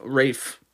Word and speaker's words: Ralph: Yeah, and they even spelled it Ray and F. Rafe. – Wow. Today I learned. – I guess it's Rafe Fiennes.